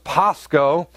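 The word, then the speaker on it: pasco (πάσχω): It's pronounced pasco. And because in the Greek, when you see the W on the end, that's actually a long O sound. So it would be pasco.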